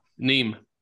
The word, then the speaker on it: Nîmes: N-I-M-E-S. How do you pronounce that? – Nîmes.